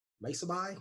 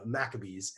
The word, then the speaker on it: Maccabees: You might not know how to pronounce that, like Maccabi? No, Maccabees. And or you might see the Gospel of Thomas in there, Shepherd of Hermes, and there's some others.